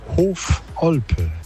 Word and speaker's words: Hofolpe: Hoffelpe, ich habe gefragt, wie wird das ausgesprochen? Und dank euch wissen wir das jetzt. Das wird aneinander geschrieben. – Guten Morgen, Steffi. Der Ort heißt Hofolpe.